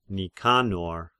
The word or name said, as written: Nikanor